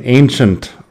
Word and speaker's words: ancient: Fíjate también la pronunciación ancient. Es un poco extraño, ¿no? Ancient. La A al principio se alarga y luego la, la CI es como ch. Ancient.